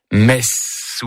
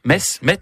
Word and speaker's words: Metz: Il s'agit bien sûr du TZ qui finit mess ou Metz. – Oui. – Metz. Dis-moi comment tu prononces Metz, je te dirai qui tu es. Si tu fais traîner les sifflantes comme le serpent ses sornettes, Metz, risquant de mélanger le nom de la plus belle ville du monde et celui d'un office religieux.